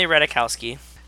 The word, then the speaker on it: Ratajkowski: Emily Ratajkowski As so, did I say it right? – Yep, you did.